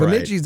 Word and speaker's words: Bemidji: By the way, did I get that pronunciation? – Bemidji State is... – Bemidji State? – Yeah, all Bemidji's right.